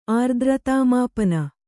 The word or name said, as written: ಆರ್ದ್ರತಾಮಾಪನ